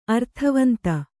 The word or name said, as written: ಅರ್ಥವಂತ